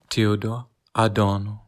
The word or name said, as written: Theodor Adorno